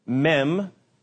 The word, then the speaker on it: Mem: The reason they add the waters on there is because of the first letter of Methuselah's name, which is the M letter in Hebrew, Mem, is how you say it, and it means water or waves.